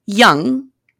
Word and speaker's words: Jung: So he decides to write his young analyst spelled J-U-N-G-I-A-N. It's pronounced young, like the word.